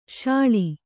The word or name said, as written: ಶಾಳ